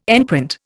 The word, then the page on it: enprint: Indian